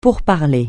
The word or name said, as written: pourparlers